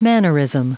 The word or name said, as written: mannerism